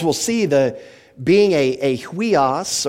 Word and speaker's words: huios: It's pronounced huios. It's H-U-I-O-S.